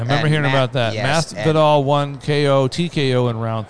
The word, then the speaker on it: Masvidal: How do you say it? Masvidal? – Yes, Masvidal and Diaz.